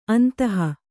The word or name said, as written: ಅಂತಹ